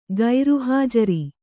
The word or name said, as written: ಗೈರುಹಾಜರು